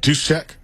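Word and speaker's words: Juszczyk: How do you say it? – Deuce check. – Is that right, or are you just saying that? – I think that's right.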